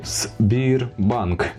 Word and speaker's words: Сбірбанк: Е, мова про так званий Сбірбанк. – Якщо ви зрозуміли, як я вимовляю одразу, то ви зрозуміли, але для інших пояснюю. Значить, буковка С потім англійською англійське слово beer, пиво та банк.